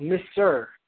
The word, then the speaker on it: Misr: Now, we know that Egypt in Arabic is called Misr, M-I-S-R. That's how it's pronounced, or transliterated.